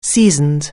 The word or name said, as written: seasoned